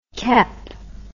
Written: kep

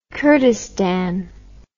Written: kordestan

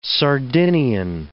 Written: Sardinian

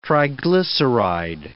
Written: triglyceride

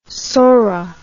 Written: sorra

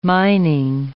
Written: mining

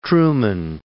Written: Truman

